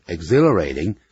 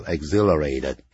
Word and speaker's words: exhilarating: This exhilarating emotion， exhilarating，也就是令人兴奋的啊。那么通常用过去分词 I felt exhilarated 就感到非常的兴奋，那个 H 不发音，不要念成 exhilarate，不是这样念 exhilarate，加一个 ED 就变成过去分词 I felt exhilarated 就等于 I felt excited，这个令人兴奋的 emotion 这种情绪啊，这种感情。